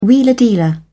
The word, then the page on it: wheeler dealer: British English